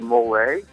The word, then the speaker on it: mole: I don't know if I'm saying it right, but mole. – You got it.